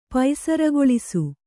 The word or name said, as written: ಪೈಸರಗೊಳಿಸು